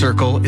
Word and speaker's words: CRCL: CRCL, pronounced Circle, is a free mobile crisis response service serving the Comox Valley.